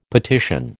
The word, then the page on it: petition: American English